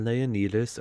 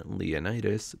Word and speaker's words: Leonidas: Also, yes, I'm using the Greek pronunciation of his name instead of the Americanized version, Leonidas, because I spent my early days in COVID lockdown studying Arabic and Greek, so let me have this one thing.